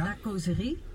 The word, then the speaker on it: La Causerie: Je bent van de wijnproeverij La Causerie. Zeg ik dat goed? – Nou, spreekt het een beetje op zijn Frans uit. Ja? La Causerie.